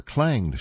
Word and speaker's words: clanged: On July 1, 2019, off Marseille, that hatch changed shut behind us after we entered the diving bell, or clanged shut, I should say. Mispronounced that word, I'm sorry.